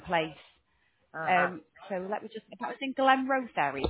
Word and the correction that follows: Glenrothes: Yeah, I think I pronounced that wrong, but yes, Glenrothes, is that correct?